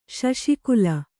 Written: ಶೌಷ್ಕಲ

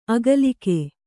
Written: ಅಗಲಿಕೆ

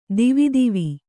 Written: ದೇವದೇವ

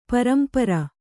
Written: ಪರಂಪರ

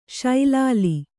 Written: ಸಲೀಲ

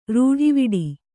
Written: ರೂಢಿವಿಡಿ

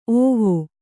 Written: ಓವಿ

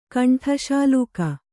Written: ಕಂಠಶಾಲೂಕ

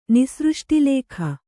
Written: ನಿಸೃಷ್ಟಿಲೇಖ

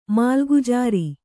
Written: ಮಾಲಗುಜಾರಿ

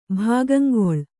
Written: ಭಾಗಂಗೊಳು

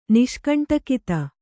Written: ನಿಷ್ಕಂಟಕಿತ

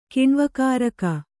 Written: ಕಿಣ್ವಕಾರಕ

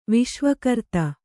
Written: ವಿಶ್ವಕೃತ್ತು

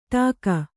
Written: ಟಕ್ಕಿ

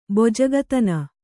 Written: ಬೊಜಗತನ

